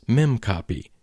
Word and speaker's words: memcpy: For example, there's a method called memcpy, usually pronounced as memcopy, that copies data from one place in memory to another.